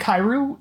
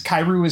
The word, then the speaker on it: Kairu: I want to say right now, Lord Kairu is a funny name because Kairu is spelled K-Y-R-O-O, but it's directly a pun on the Japanese word for frog, Kairu.